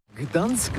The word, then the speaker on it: Gdańsk: on voit tout en vert ici à Gdańsk. Alors d'abord, comment prononce-t-on bien ? Ça s'écrit G-D-A-N-S-K, on dit Gdańsk...